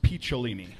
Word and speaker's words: Picciolini: It is pronounced Picciolini, like okay.